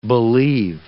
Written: believe

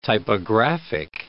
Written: typographic

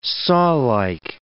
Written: sawlike

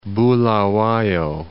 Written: Bulawayo

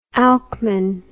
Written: Alcman

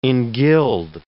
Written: engild

